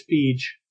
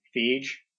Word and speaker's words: Feige: I think it's Feige. – Feige? – That's how I've always pronounced it.